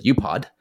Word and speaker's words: UPOD: It's an acronym spelled UPOD, and I pronounce it UPOD, which stands for under promise and over deliver, right?